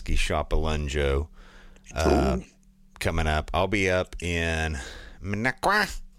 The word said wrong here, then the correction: Minocqua: Minocqua. – Minocqua.